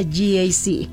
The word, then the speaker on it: GAC: Se escribe GAC. pero se pronuncia GAC. Repítelo, GAC Angelópolis, donde el tráfico termina y el manejo empieza.